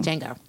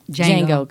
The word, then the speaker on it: Django: I mean, I'm saying it wrong. Django. – Django, because I get caught up with that D.